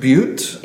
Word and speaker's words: bhut: Ghost Butte. – Yeah, it's B-H-U-T. – B-H-U-T.